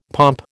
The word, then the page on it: pomp: American English